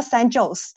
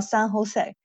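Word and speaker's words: San José: 所以我以前是说以为这个是 Jose，但是其实它是 Jose，那个元音是要发出西班牙的元音来的。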